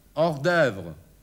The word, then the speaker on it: hors-d'œuvre: Nicht Hors-d'œuvre, sondern Hors-d'œuvre. A vous. Hors-d'œuvre. Hors-d'œuvre. O, E zusammengeschrieben plus U wird ausgesprochen Ö.